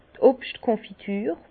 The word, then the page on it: d' Obschtkönfitur: Bas Rhin; Herrlisheim